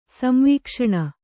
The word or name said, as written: ಸಂವೀಕ್ಷಣ